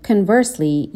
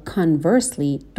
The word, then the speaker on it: conversely: کلمه conversely یا conversely دو تا تلفظ داره. هم میتونیم با صدای شوا تلفظ بکنیم بگیم conversely، هم میتونیم با صدای آ تلفظ بکنیم و بگیم conversely.